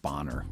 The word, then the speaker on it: Boehner: And, of course, there was John Boehner, the congressman who I referred to, the House Minority Leader, earlier. I mispronounced his name. John Bonner.